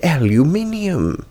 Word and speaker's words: aluminium: You're mispronouncing the word aluminium. – No, I'm correctly pronouncing the word aluminum.